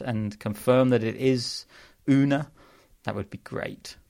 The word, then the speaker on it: Rune: But it turns out that Holger Rune doesn't pronounce the R. So it's apparently Holger Une, which has really caused me some problems. So we're going to go with Holger Une.